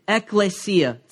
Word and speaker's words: ekklesia: But the original word in the Greek is ekklesia, spelled like this, ekklesia.